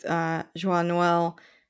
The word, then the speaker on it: Joyeux Noël: I noticed, you know, online it's written both Joyeux Noël.